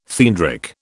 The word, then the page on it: theandric: British English